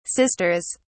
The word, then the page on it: sisters: British English